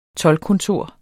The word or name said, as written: toldkontor